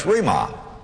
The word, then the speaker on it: rhema: But there's another word that is translated word, and it's rhema. R-H-E-M-A. Rhema. Or rhema. However you choose to pronounce it.